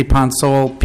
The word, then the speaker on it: Ponsolle: Ponsole, P-O-N-S-O-L-L-E.